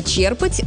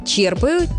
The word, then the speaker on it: черпать: У глагола «черпать» ударение устойчивое, оно сохраняется и в других формах. «Черпаю», «черпаешь», «черпают», «черпал».